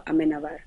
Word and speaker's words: Amenábar: So the original is directed by Alejandro Amenábar. – Yes, Alejandro Amenábar.